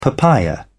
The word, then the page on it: papaya: British English